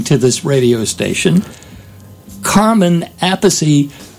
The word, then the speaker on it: Appice: And all these years, I think I've been saying Appice as the rest of the world, but apparently just found out it's actually pronounced Appice. – Appice.